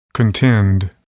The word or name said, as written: contend